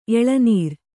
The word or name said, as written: ಎಳೆನೀರು